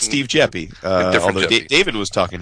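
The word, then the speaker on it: Jeppe: And Mr. Jeppy, I hope I'm saying his name right, J-E-P-P-E. I believe it's Jeppy, right? – Mm-hmm.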